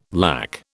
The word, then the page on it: lack: British English